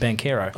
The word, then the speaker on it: Banchero: Oh, as I learned today, it's pronounced Banchero.